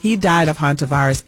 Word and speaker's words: Hantavirus: Hantavirus. – Hantavirus.